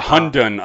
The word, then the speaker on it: Hundun: Uh, Morris, uh, the Honda, uh, Hunden. – Yeah, Hundun, I think it's pronounced. H U N D U N, I believe, is the is how it's spelled uh, Morris was voiced by uh, like the animal noises was voiced by D.